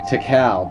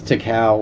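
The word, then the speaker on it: Tical: I'm very glad to be pronouncing that correctly. I think last time I called it Tikal. And I don't know why, because he says Tikal, like, a lot.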